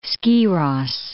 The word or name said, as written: Skiros